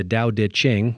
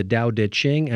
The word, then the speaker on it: Tao Te Ching: The ancient text is the Tao Te Ching, yes, spelled Tao, but pronounced with D sounds.